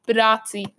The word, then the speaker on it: Bitadze: Bitadze, that's how I pronounce it.